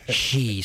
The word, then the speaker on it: Xi: President Xi, it's with an X and an I, but it's pronounced she. Sometimes I say G when I'm tired, you know.